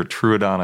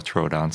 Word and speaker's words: Troodon: Speaking of Troodon, I never know how to say it. I hear Truodon, I also hear Troodon, but I'll go with Troodon since that's what you're saying.